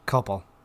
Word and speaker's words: Copple: After a year of searching for the murderer, police finally arrest Eric, spelt C-O-P-P-L-E. How would you pronounce that? Coppel? – Coppel. Yep, Eric Coppel.